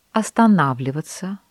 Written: останавливаться

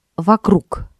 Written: вокруг

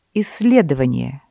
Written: исследование

